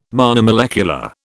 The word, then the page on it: monomolecular: British English